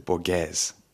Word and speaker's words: Borges: I'm going to pronounce it Borges to avoid just really making myself seem pretentious.